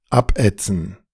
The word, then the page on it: abätzen: [ˈapʔɛʦən]